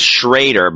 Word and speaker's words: Schroeder: It's pronounced Schrader, by the way, he says to me. Looks like Schroeder, but it's Schrader.